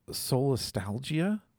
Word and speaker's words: solastalgia: And you introduced to us this concept of solastalgia.